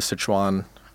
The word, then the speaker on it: Sichuan: In the context of that, you also tell the story of the Sichuan, forgive my mispronunciation, sure.